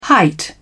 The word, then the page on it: height: British English